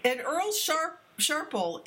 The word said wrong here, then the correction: Sharples: is, am I pronouncing his name correctly, Earl Sharple? – Sharples, yes.